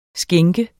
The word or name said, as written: skænk